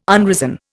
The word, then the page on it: unrisen: Indian